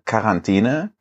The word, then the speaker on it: Quarantäne: Ich habe das immer mit so einem Q gesprochen. Jetzt habe ich genannt. – Ach, Quarantäne hast ja, du gesagt. Quarantäne. gesagt. Wie Quark.